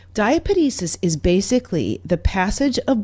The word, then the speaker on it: diapedesis: So another thing that happens, though, when you've got this inflammation is something called, all right, wait for it, diapedesis. That's right. I'm going to spell it for you. Just, I love this word, diapedesis.